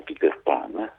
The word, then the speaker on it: Peter Pan: No, della letteratura per l'infanzia, che quello si pronuncia in inglese perché il suo autore è inglese, quindi si pronuncia Peter Pan, diciamo così.